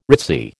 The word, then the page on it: ritzy: American English